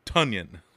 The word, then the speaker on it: Tonyan: It's oh, T-O-N. At the end. It's T-O-N, though. – Y-A-N. – How do you... Tonyan. I'm gonna spell a word. Tell me how you pronounce it. – Okay. – Ton. T-O-N. – Tonyan. – Tonyan. All right. Now you got it.